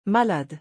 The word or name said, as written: malade